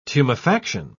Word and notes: tumefaction: British English

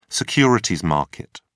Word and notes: securities market: British English

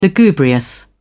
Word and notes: lugubrious: British English

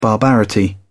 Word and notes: barbarity: British English

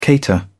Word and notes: cater: British English

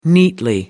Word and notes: neatly: American English

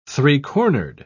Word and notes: three-cornered: British English

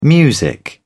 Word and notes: music: British English

